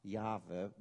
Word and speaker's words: Jahwe: Im Hebräischen werden ja keine Vokale geschrieben, da werden nur die Konsonanten geschrieben. Das heißt J-H-W-H, Jahwe wird es ausgesprochen, beziehungsweise meint man, dass es früher ausgesprochen wurde.